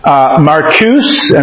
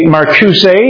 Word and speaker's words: Marcuse: Some people say his name Marcuse, and some people say Marcuse. Marcuse is the way Americans say it. Marcuse is the way British people say it.